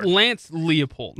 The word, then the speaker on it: Lance Leipold: It's Lance Leipold. – Lance Leipold.